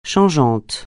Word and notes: changeant: francais

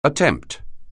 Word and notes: attempt: anglais

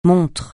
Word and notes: montre: francais

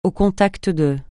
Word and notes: au contact de: francais

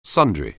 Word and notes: sundry: anglais

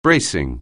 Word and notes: bracing: anglais

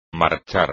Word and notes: marchar: Spanish

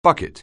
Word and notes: bucket: anglais

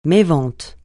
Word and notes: mévente: francais

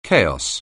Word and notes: chaos: anglais

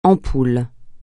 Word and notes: ampoule: francais

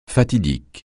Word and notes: fatidique: francais

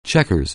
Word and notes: checkers: anglais

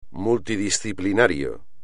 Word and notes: multidisciplinario: espagnol

